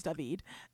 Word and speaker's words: Daveed: His mother is Jewish, which is why his name is David. And it's like, so David is how Hebrew people pronounce like David, or like the Hebrew pronunciation of David. – Right. – But it's spelled D-A-V-E-E-D because his non-Jewish father preferred it to be spelled that way.